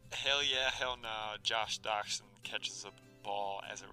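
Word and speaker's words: Doctson: Doxson. Got it. Like the dog. Like the little wiener dog.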